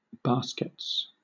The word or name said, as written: baskets